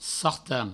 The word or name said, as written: sartan